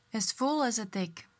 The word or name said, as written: full as a tick